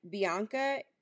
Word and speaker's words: Bianca: It was because when I was little, like, Bianca is, for me, was, like, very hard to, like, articulate or pronounce. Like, people would always be like, what? Like, how do you spell it? B? They would, like, butcher it. Like, B-E-H-Y.